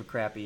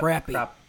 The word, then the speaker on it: crappie: I have heard of a crappy, yeah. – Crappie? – Yep. Crappie. – Crappie. – No, in Ohio it's pronounced crappy. Because everything out of Ohio is crappy.